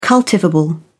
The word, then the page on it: cultivable: British English